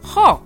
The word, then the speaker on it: ho: Das Problem ist, man muss nicht das H aussprechen. Zum Beispiel, io o, ich habe, man schreibt o nicht nur mit o, sondern auch mit ho am Anfang. Io ho. Aber die Italiener sprechen nicht das o aus.